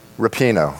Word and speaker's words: Rapinoe: Am I pronouncing that correctly? – Rapinoe. – Thank you. Megan Rapinoe.